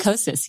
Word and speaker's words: Kosas: Am I saying it right? Kosas? – Kosas, yes.